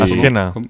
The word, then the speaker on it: Azkena: Azkena, es parecido a una, el sonido de una S. – Azkena, ¿qué Azkena. Tal? ¿Así mejor? – Así mejor. – ¿Pero eso cómo se escribe? ¿Alguna H, alguna X por ahí, quizá? – No. – Una Z como Zaragoza. Es una Z. – Como Zaragoza. ¿Cómo? – Se escribe igual que se escribe. – Z pronunciada como en inglés.